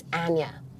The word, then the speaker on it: Anya: It's Anya. – Anya. – Anya, yeah. Mm-hmm. I'd say like aneurysm.